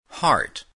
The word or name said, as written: heart